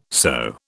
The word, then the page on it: sew: British English